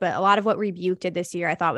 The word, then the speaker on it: Rebuke: Is it Rebuke? Is that how you say his name? I think it's, yes, Rebuke. – I don't know. The Rebuke remix that came out towards the later end of the year.